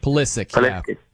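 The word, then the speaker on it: Pulisic: And they're going to look different with Polisic. Hopefully I said his name right. That's Polisic. – The name, right? – Polisic.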